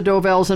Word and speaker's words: Dovells: The Del Vos. – Number six. – Del Vos. I'm not saying it right. – That's all right. You know it's the Dovells, and I'll give it to you for that.